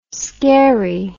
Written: scarey

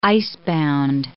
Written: icebound

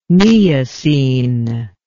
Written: neocene

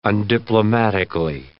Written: undiplomatically

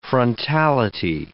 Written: frontality

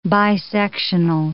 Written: bisectional

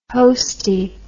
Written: hostie

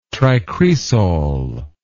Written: tricresol

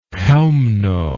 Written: chelmno

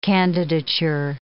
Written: candidature

